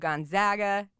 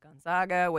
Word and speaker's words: Gonzaga: So Spokane is the home of Gonzaga, not Gonzaga, which was the home of Brandon Clark until last night when he officially became a Memphis Grizzly.